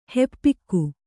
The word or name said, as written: ಹಿಪ್ಪೆಗ